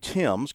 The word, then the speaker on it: Thames: He also goes by, as he was telling me, Robbie Timms, because his name is English and it's spelled just like the Thames River over there in jolly old England.